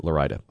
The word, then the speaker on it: Laurita: His name is James Larida, or Larida, L-A-U-R-I-T-A. – L-A-U-R-I-T-A. Got it. How are you pronouncing it? – I'm pronouncing it Larida.